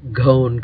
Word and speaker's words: Ghon: It's a gone complex. So that area of your lung is all gone. Gone is spelled G-H-O-N, named after presumably Dr. Gone.